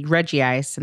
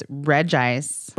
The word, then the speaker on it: Regice: Well, I used to say Regice, and then I got schooled. – And what is it? – And then the person said, it's Regice. – Regice. Mm. – Regice.